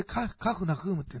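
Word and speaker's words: カ フナ フ ームっ (כפר נחום): ね、イエスがカペナウム、このカペナウムというのはイエス様が、この、まあ、宣教活動された中心の、まあ、ガリラヤ湖畔の、まあ、村ですね、町というか、まあ、町、まあ、当時町かな、町ですけども、これは、ヘブル語でカフナフームっていうんですね、ちょっと発音が、あれですけども、で、これは、慰めの村、慰めの町っていう意味です。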